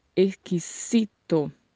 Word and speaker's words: exquisito: No espanhol não tem a sonoridade de Z nas palavras do espanhol. Onde você encontrar a letra Z, pronuncia com som de S. E onde tiver S, pronuncia com som de S. Então, a palavra em espanhol é exquisito.